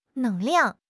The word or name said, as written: 能量